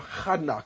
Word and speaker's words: Enoch: He had Enoch, and some people call him Hanok. If you look at the way it's spelled, it's Hanok.